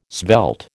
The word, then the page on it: svelte: American English